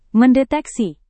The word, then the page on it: mendeteksi: Indonesian